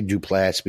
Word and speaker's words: Duplass: I say Duplass because the final syllable is ass, A-S-S.